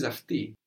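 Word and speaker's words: afti (αυτοί): The plural of aftos is afti, but this afti is spelled O-I. So I mentioned that the O-I spelling of the E sound is associated with plurals.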